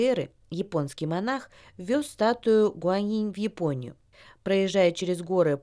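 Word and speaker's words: Куаньинь (观音): Гуань означает «смотреть», произносится в первом тоне, а инь – это звук, читается тоже в первом тоне. Куаньинь – богиня милосердия и сочувствия.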